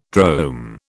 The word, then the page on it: drome: British English